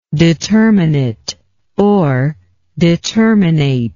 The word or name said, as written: determinateness